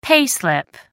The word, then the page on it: pay slip: American English